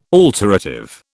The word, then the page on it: alterative: British English